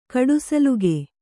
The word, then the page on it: ಕಡೆಸಿಲ್ಕು: kaḍesilku